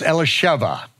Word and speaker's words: Elisheva: It's not Elizabeth, it's Elisheva, okay? It's not Abib, it's Aviv.